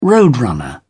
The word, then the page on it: roadrunner: British English